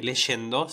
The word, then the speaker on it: leyendo: la palabra eh, leyendo ¿sí? se pronuncia con la sh eh, cuando tiene la y.